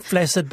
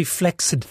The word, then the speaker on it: flaccid: Apparently it's true. It's become flaccid, but it's actually flaccid.